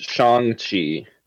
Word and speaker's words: Shang-Chi: I didn't know this until recently, but it is actually pronounced Shang-Chi. – Okay. – And I have been mispronouncing it my entire life, so that is not meant to shame anyone.